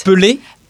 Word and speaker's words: Pelé: It's Pelé. – Pelé, Pelé. Pelé. Yeah, yeah, yes. Of course, I know Pelé. Come on. Pelé, Maradona.